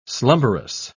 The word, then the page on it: slumberous: British English